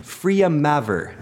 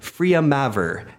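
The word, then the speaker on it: Freya Mavor: It's Freya Maver. Freya Maver. F-R-E-Y-A space M-A-V-O-R.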